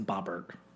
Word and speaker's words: Boebert: Sweetie, she's from some state where they do not pronounce it like that. It's Boebert.